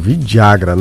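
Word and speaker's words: Villagra: Que alguns confundem aí com o nome daquele remédio, mas não é, Vidagra, né? Com 2 L's. É isso, Herrera? A pronúncia é essa, né? – 5 horas.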